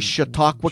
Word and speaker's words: Chautauqua: Southern Erie, Cattaraugus, Chautauqua County.